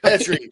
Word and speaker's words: Petrie: We got to get you saying it right. Petrie, God bless it. bless it. I know. I know this. I've been corrected. I've been corrected many times, and I, I, I just forgive me, guys.